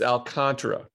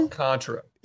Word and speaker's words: Alcantara: It's Alcantara. – Is it though? – Alcantara. Yes. It is 100%.